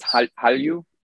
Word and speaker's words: Hallyu: One of the words that was added is Hallyu, right? Hallyu, the Korean wave. And that's spelled Han. You, there's an Hanlyu, and those get merged.